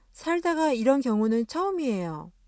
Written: 이런

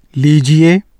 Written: لیجیے

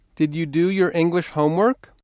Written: homework

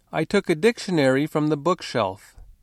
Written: bookshelf

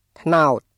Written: ត្នោត

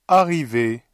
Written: arriver

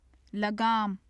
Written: lagaam

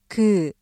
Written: 그

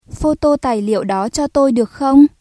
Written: photocopy

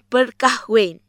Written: berkahwin

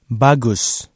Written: bagus

